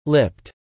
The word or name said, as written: lipped